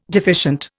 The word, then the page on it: deficient: Indian